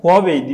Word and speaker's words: Huawei: E, yanılmıyorsam Huawei diye okunuyor. Çinler kendileri başka okuyor sanırım ama hani uluslararası pazarda Huawei diye okuyorlar. Bir de Xiaomi diye yazılan, Xiaomi diye okunan başka bir Çinli premium marka var.